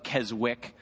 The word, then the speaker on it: Keswick: It looks like Keswick, but the W is silent.